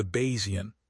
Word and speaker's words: Bayesian: We love the idea of a whole-body scan to solve all our problems, but we ignore the Bayesian, pronounced B-A-Y-Z-U-N, reality that testing rare events in healthy people leads to a mountain of false alarms.